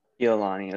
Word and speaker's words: Iolani: Uh, uh, Ilani High School in in Honolulu, Hawaii. Did I pronounce that correctly? – Iolani It's close.